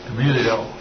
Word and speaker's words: Gamaliel: I can't say that right. Gamaliel? – Gamaliel.